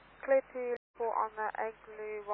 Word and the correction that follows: ELGUX: Roger, and that's the Elgux, Echo Lima Golf Uniform X-ray. – The Elgux, sorry, pronunciation wrong, Rhino 1 Alpha Alpha.